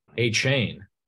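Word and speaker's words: Achane: I believe you pronounce his name A-Chain.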